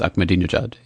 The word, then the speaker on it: Ahmadinejad: And so you say to yourself, well, is Ahmed, pronounce his name for me, please. Ahmadinejad? – Ah, yes, Ahmadinejad, yeah. – Is that, that's close enough, okay.